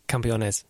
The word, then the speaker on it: Campeones: Campeones. – There we go. – Campeones Cup. – Because coming from the north of England, my accents... – You can't speak English, let alone say Campeones.